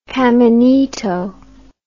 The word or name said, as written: caminito